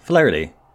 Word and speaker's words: Flaherty: Flaherty. That was like a Southern accent. Yeah. Like from Ragtime. – Yeah. Same team. How do you pronounce his name? – Flaherty.